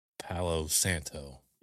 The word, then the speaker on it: Palo Santo: Palo Santo.